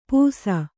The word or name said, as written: ಪೂಸ